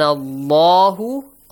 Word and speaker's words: Allah: Wakan Allahu. Allah is pronounced with a heavy sound.